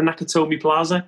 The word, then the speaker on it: Nakatomi Plaza: is it the Nakatomi Plaza? – I think that's right, yeah. – Is that right, J-Dog? Am I saying that right? – It is, yes.